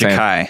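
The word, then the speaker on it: Jachai: How do you pronounce his first Ja'Kai.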